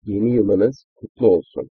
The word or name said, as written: yeni yiliniz kutlu olsun